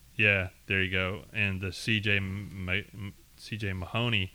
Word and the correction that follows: Mahaney: Mahaney, yeah.